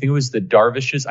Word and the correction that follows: dervish: I never pronounced this correctly. Blake will correct me. – Like a whirling, like a dervish? Like the Islamic warriors? – Yes, dervish. Yes.